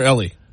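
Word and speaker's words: Elie: Is it Eli or Ellie? I'm pretty sure it's Ellie Wiesel.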